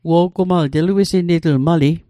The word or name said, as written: wo'gumal